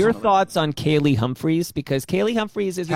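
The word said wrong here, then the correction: Kaillie: You mean Callie Humphries? – Is that how you say it? Callie?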